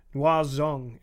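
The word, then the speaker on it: Người Rừng: Yeah, Batututs and the Nguo Zung, and it's spelled Nguoi space Rung, but it's pronounced very differently.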